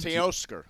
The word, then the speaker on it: Teoscar: Teoscar.